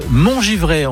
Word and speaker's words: Montgivray: Alors là, faut m'expliquer parce que Bertrand m'a dit hors antenne, Laetitia habite Montgivray. On prononce comme ça.